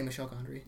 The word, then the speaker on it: Michel Gondry: Did I not say Michel Gondry? – No, you did.